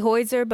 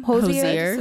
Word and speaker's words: Hozier: I say Hoiser, but how do you pronounce his name? – Hozier.